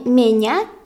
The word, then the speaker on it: ме: Впрочем, и в слове «ме» то же самое. «М» перед «е» не смягчается.